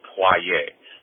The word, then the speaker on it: Poirier: The beginning of his last name is actually making a pwa sound, P-W-A, in kind of English spelling. So the first syllable in his last name is pwa, and the R-I-E-R spelling makes a ye, so it's Poirier. So how you say his whole name is Vincent Poirier.